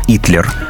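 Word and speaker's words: Hitler: При этом имя кандидата по-испански произносится как «Итлер».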